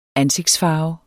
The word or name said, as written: ANSIGTSFARVEN